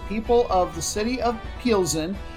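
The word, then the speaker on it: Plzeň: So in this case, the city of Pilsen, which is spelled P-L-Z-E-N, at least in the English language. And it is pronounced Pilsen like Pilsener.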